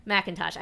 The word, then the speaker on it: McIntosh: Okay, McIntosh. I just looked over. My wife is here, and she is from Georgia. So it's like Georgia thing. – Georgia. – McIntosh County, Georgia.